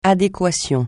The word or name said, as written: adéquation